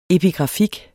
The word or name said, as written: epigrafik